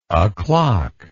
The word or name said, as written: oclock